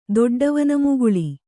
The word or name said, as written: ದೊಡ್ಡವನಮುಗುಳಿ